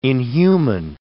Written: inhuman

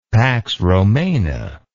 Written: pax romana

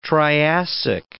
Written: Triassic

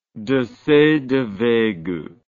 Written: Desaix de Veygoux

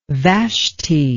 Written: Vashti

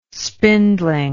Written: spindling

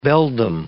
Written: beldam

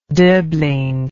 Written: doblin

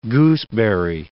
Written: gooseberry

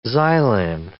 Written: xylan

